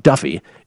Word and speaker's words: Daffue: MJ Daffue, which I have no idea how that's pronounced Duffy, D A F F U E, but apparently that's South African for Duffy.